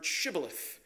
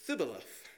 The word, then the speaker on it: shibboleth: And the people from Ephraim, they have a hard time saying this word. And they say thibboleth. I don't know. But they can't say it. They can't say it right.